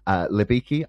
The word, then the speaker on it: Libicki: Today I have the pleasure of being joined by Dr. Martin Libicki. Have I pronounced that correctly, actually? – It's Libicki in the United States. It's Libicki in Poland.